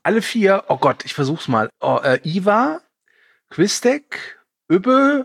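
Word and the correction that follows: Hvitserk: Ja, der wird auf Deutsch Quitzak ausgesprochen. Wenn man mal rumguckt, wie das geschrieben wird, weiß ich nicht, ob das tatsächlich so ist.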